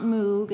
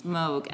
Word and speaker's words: Moog: A Moog is, it's not Moog, as many people think it's supposed to be pronounced. It's Moog, M-O-O-G, which is a synthesizer.